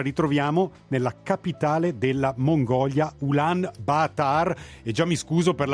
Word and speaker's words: Ulaanbaatar: Allora, la pronuncia andava bene, prima cosa? – Sì, andava abbastanza bene. Se uno deve essere proprio giusto giusto col mongolo, praticamente loro mangiano tutte le vocali, solo leggono quando sono doppie, quindi è Ulaanbaatar.